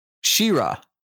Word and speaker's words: She-Ra: You put three more A's in it than it actually has. And the emphasis is on a different part of the word. Say it again. – She-Ra. – Yeah, your emphasis is on the she.